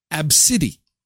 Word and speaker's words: Abcde: A, B, C, D, E, and you pronounce it Absidi, Absidi.